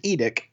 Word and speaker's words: IDIC: It's pronounced EDIC, and it's Infinite Diversity in Infinite Combinations, Paul.